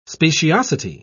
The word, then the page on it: speciosity: British English